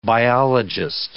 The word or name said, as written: biologist